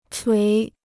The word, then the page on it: 捶: chuí